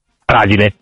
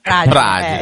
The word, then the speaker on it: Fragile: Tu come lo pronunci, Fragile o Fragile? – Fragile. – Fragile, esatto, fragile.